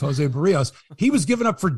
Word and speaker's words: Berríos: And it is Berríos because I've been listening to Dan Schulman say his name and I've got it, Mike.